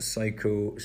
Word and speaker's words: psycho: Now, I know that psycho starts with a P, but for the purposes of our acronym, it's going to start with an S because the P is silent after all.